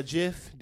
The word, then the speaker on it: GIF: If you call it a GIF, that's fine. But I just want to let you know that the actual pronunciation is GIF.